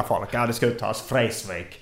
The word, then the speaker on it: Vreeswijk: Eller Vreeswijk som han själv uttalade.